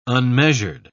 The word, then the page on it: unmeasured: British English